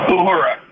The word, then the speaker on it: Uhura: Uhura, but yes. – Yes, Uhura. How do you pronounce it? Ohura? – Uhura.